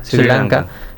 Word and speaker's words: Sri Lanka: Su pareja es de Sri Lanka, así se pronuncia ahora. Sri Lanka.